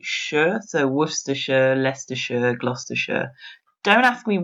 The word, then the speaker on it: Worcestershire: We don't kind of pronounce a hard C. So we would say Worcestershire. – Worcestershire.